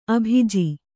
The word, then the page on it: ಅಭಿಜೆ: kannada